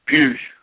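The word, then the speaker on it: Puig: So the thing about Pew, do you say it Pug or Pew? – Pugh. – Pugh? Okay. – That's how I pronounce it, P-U, it's P-U-I-G, I think it's pronounced Pugh.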